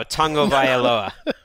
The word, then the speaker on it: Tagovailoa: You are supposed to say tongue to start. – Oh, that's cool. – So tongue Tua? – No, Tagovailoa.